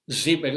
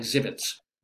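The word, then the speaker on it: Żywiec: Well, it's Z-Y-W-I-E-C, if that's helpful at all. Zibitz, it's pronounced like Zibitz.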